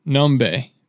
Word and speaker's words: Nombe: Numb, oh, almost said it wrong. Nombe, This guy, Noah McBeth, Nombe, he is like unreal talented.